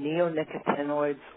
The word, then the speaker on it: neonicotinoids: So, and I pronounce them neonicotinoids or neonics for short.